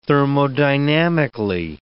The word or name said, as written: thermodynamically